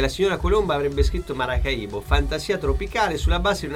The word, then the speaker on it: Maracaibo: l'accento sulla I di Maracaibo. – Perché in realtà si chiama Maracaibo.